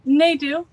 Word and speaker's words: Nadeau: So I guess, well, the first question is, let me make sure we're pronouncing your last name right. It's Lori Nadeau. Is that right? – Nadeau. – Nadeau. Okay. Either the emphasis way. On Nadeau. Okay.